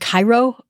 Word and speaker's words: chairo: It sounds like the word Cairo, C-H-A-I-R-O, long O.